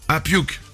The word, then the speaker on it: puke: Puck, ça veut pas dire vomir en anglais. – Puke. – Ouais, mais mal prononcé, mal écrit, ça fait puck.